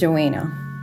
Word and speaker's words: Joanna: But then if I say, oh, my name is Joanna, it's a very slight changing the tone of how I pronounce the second A in my name.